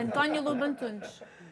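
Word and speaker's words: António Lobo Antunes: Tem uma crônica linda, aliás, do Antônio Lobo Antunes. Como é que fala aqui, Antônio Lobo Antunes? – É a mesma ah, tá. coisa, desde em português é Antônio Lobo Antunes.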